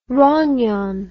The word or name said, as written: rognon